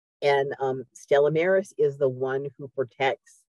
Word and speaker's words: Stella Maris: Stella Maris, am I saying that right? – Yeah, Stella Maris is the Blessed Mother Mary of the Sea.